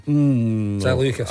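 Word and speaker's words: Žaliukas: The late, great Marius Soliska. – Zaliukas. – I'll let you away with the mispronunciation. Zaliukas.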